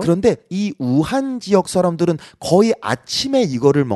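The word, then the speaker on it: 武汉: 자 2위는 잘 모르실 텐데 이거는 우한. 한국어 발음 무한이라는 네네. 지역입니다. 무한에 그 热干面이라고 있어요.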